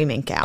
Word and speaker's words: Minkow: Let's get more upset about Barry Minkow. – Okay. I'll take your word for it. – That's how they pronounced it in the documentary I watched.